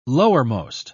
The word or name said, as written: lowermost